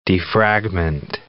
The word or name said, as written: defragment